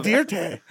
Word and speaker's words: Dirte: How do you spell Dirtay? – D-I-R-T-E. Because in the movie, oh, yeah. – they go... Joe Dirt, and he goes, I like to put an E on the end, pronounce it Dirtay.